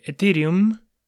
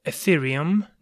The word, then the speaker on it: Ethereum: de Ethereum o Ethereum, depende cómo lo quieras pronunciar, porque es la única manera de transformar eso en una moneda estable para seguir haciendo otros cambios.